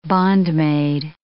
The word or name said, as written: bondmaid